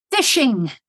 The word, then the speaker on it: phishing: Phishing, which when you first hear it, it doesn't sound the way it's spelled in IT, which is P-H-I-S-H-I-N-G. Phishing, which is part of spoofing, etc.